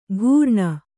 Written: ಘೂರ್ಣ

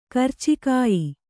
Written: ಕರಚಿಕಾಯಿ

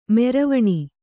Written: ಮರವಣೆ

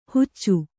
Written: ಹುಚ್ಚು

